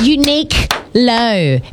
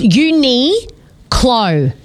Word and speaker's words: Uniqlo: Uniqlo, the same as the woman on the answering machine. – It's not unique low. It's uni clo.